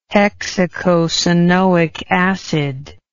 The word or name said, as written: hexacosanoic acid